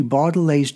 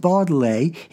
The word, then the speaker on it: Baudelaire: In the case of Baudelaire, he gave the example of thinking of the word vaudeville to pronounce the word Baudelaire.